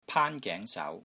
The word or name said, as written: PAAN1 GENG2 SAU2